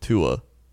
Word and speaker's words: Tua: It says the first part of Tua Tagovailoa's name is fairly straightforward. It's pronounced as it is spelled, Tua.